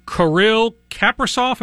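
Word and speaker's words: Kirill Kaprizov: Is the pronunciation, is it Kirill Kaprasov? Am I saying that right? – Yeah, that's correct. That's correct.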